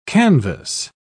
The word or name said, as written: canvass